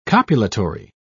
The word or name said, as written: copulatory